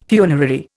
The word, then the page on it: funerary: Indian